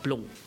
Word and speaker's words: Blanc: En Chenin Blanc se uitspraak is eindelijk anders. As jy hom nou reg sê, is dit 'n blonk. – Blonk. So Franse uitspraak.